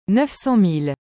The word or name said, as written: 900000